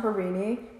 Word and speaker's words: Harini: It's like every letter is pronounced Harini, H-A-R-I-N-I.